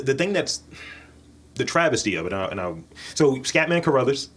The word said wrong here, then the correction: Crothers: Crothers, because it's not Carruthers.